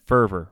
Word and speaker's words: fervor: Morelli likes that, it seems like he likes that fever. He likes that that fervor, rather, if we pronounce it properly in English.